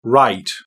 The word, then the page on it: write: British English